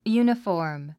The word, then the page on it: uniform: júːnəfɔ`ː r m; ユニフォーム